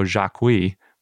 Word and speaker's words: Jacuí: And then Jacuiensis is after Rio Jacuí, which is a nearby river.